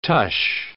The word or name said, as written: tush